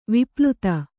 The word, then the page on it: ವಿಪ್ಲುತ: vipluta